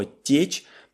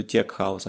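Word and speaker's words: tech house: поэтому когда говорят тех-хаус вместо тек-хаус, это окей, но течь – это просто, просто ужас.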